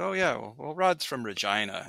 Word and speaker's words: Regina: But so you grew up in Regina, which everyone outside of Canada just thinks it's a name that rhymes with fun, right? – Yep.